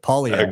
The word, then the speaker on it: Poilievre: Poilievre.